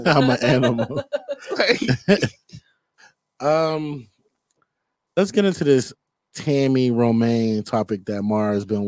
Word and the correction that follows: Roman: You guys know who, did I say her name right? – Tammy Roman.